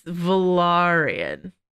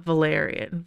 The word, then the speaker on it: Velaryon: It's Velaryon, not Valerian.